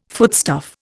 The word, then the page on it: foodstuff: Indian